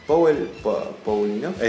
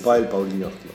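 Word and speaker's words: Paweł Pawlikowski: Powell Paulinowski. – Eso sí que no se dice así. – Mierda se dice. – Powell Paulinowski. – Ahí está.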